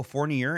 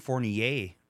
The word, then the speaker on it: Fournier: So um both pronunciations. Oh, Fournier and Fournier.